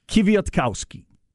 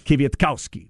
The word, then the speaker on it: Kwiatkowski: But it is Coach Pete Kwiatkowski of Washington. The name. – I'm sorry. Say that one more time. – Kwiatkowski. Five sounds. Punch the cow. – Got some vodka with that pronunciation? – Kwiatkowski.